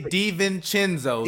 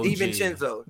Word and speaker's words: DiVincenzo: He's a Dante DiVincenzo. – DiVincenzo. – Dante DiVincenzo. I'm behind here working the boards. And listen, Dante DiVincenzo. You've said this man's name wrong for like 4 years straight.